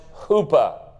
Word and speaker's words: chuppah: They called that house a huppah, C-H-U-P-P-A-H, pronounced huppah.